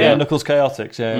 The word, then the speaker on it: Chaotix: I was like, I think it's pronounced Quatox. I was like, and then it wasn't until I heard on a VHS it's Chaotix.